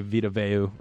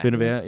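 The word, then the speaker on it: Vita Vea: No shot they stop Tampa Bay, Vita Veu, right? Am I pronouncing that correct? – That is correct. – Vita Veu, yeah.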